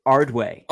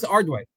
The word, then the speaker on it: Ardoin: I think it's Ardway. – Silas Ardway. – It doesn't look like it, but it's